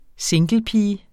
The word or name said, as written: singlepige